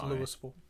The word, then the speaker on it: Louisville: Now I just got to correct his pronunciation because it's Louisville.